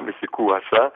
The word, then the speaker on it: ME2SEKO2SA: Alors, ça s'écrit M-E-2-S-E-K-O-2-S-A, mais c'est quoi ça?